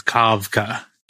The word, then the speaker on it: Kafka: And when she created that character, she created the character's name as a reference to Kafka, Franz Kafka, who the original Czech pronunciation of Kafka, K-A-F-K-A, was Kavka, K-A-V-K-A.